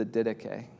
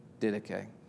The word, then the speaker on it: Didache: One of the earliest documents of the church outside the New Testament is the Didache, which I think is how you say it. Is that right? Didache. I always said Didache, but apparently that's wrong.